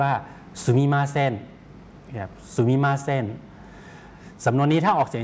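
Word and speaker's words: สุ มิ ม า เซ น (すみません): ตัวเซกับตัวอึนะครับหรือตัวอึในภาษาอังกฤษเนี่ยนะครับคนญี่ปุ่นออกเสียงรวบยาวไปเลยออกเสียงว่าเซนครับออกเสียงรวบแล้วลากยาวนะครับเซนครับสุมิมาเซน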